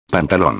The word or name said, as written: pantalon